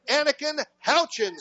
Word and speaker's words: Houchin: A sack by Hudson Hall, a recovery of that fumble by Anakin Houchin, Steve. – Yep, you got all that right, and you said it right, just like I would have said.